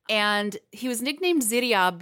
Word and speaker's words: Ziryab: I'm going to pronounce it the way that I heard it pronounced. – Okay. – So don't get mad at me for being like on Jeopardy and using an accent to pronounce something that isn't an English word. okay. So this man, his name is Ziryab.